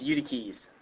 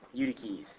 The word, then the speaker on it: Eutyches: All right, this guy's name is pronounced Eutyches. Eutyches.